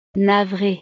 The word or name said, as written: navree